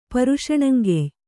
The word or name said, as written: ಪರುಷಣಂಗೆಯ್